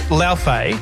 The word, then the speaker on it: Laufey: Now, the pronunciation is a bit of a tricky one. It's Laufey.